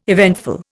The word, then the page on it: eventful: Indian